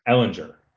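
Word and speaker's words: Ehlinger: Erlinger. – Yeah, sure. All right. – What's how you say his name? – That's perfect. – Ellinger. – Yeah, that's right.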